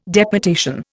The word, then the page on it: deputation: Indian